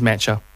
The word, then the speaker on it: Mecca: That word is mecha. – There's two C's. – Yeah, mecha. – It's pronounced mecha. – Mecha Bingo. M-E-C-C-A.